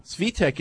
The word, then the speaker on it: Svitek: He also pronounced Svitek with a short I. – He often butchers names. – I'm always entertained with the banter and debate regarding name pronunciation. – What's the short I? – Keep it up. – Svitek? – That's a long I. – Yeah. – Oh. – Svitek is how it is pronounced.